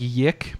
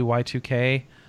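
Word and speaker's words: YIIK: Yeah, and it's spelled, it looks like Yik, but it's actually Y2K.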